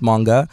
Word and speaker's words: Moonga: So it's pronounced Moonga.